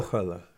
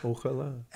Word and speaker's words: Ojalá: Ojalá. – Yeah. Ojalá. – Ojalá. – Acts on the last syllable.